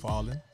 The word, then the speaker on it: Fallon: It's Fallon.